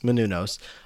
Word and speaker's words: Menounos: Maria Menounos? Menounos. – Menounos.